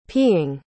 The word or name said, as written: peeing